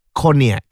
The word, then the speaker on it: Cognac: C-O-G-N-A-C, how to pronounce? – Cognac. – Oh, Cognac ya.